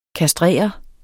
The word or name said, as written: kastrere